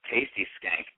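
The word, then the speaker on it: Tasti Skank: And let's talk a little bit about this Tasty Skank band you're in. Am I pronouncing that correctly? – Yes, T-A-S-T-I, Skank, one word.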